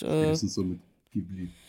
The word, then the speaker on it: Ghibli: Im Japanischen wird es halt Ghibli ausgesprochen. – Ah, okay. Ghibli. – Ja, fast, geil. Ja. Also quasi Ghibli, so. Aber in Japan wird oft das R nicht so klassisch ausgesprochen wie hier.